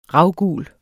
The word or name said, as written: RAVGULE